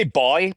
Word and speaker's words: buoy: I would say boy, by the way.